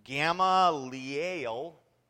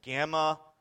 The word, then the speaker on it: Gamaliel: Oh, 34 says, but a Pharisee in the council named Gamaliel, that's how it's pronounced, and I've been calling him Gamaliel for I don't know how long all week, but I guess it's Gamaliel.